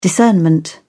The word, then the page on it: discernment: British English